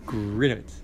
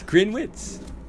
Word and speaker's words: Grinowitz: Grinowitz. – It's like grin, like you're smiling. – Grin. – Wits. – Wits. Grinowitz. Nice.